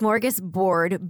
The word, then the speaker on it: smorgasbord: Yeah, we had a discussion before the pod on how to pronounce smorgasbord or smorgasbord. – What's that effect called? The Bernstein Bears, the Bernstein Bears. The Mandela effect. – There we go. The Mandela effect. Yeah, it's smorgasbord, B-O-R-D. – Smorgasbord. – Not B-E-R-G, which I think a lot of people think that it is.